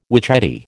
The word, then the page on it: witchetty: American English